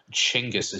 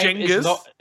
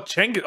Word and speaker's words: Chinggis: Or Chinggis, as it's apparently pronounced. – What? I'm sorry, wait. What? – So his name Genghis? is not Genghis Khan or Genghis Khan. It's pronounced almost like a CH. So Chinggis.